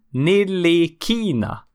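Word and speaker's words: Ntilikina: By the way, man uttalar Franks efternamn Nillikina. Inget T.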